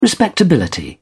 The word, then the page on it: respectability: British English